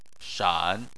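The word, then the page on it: shan: tone 3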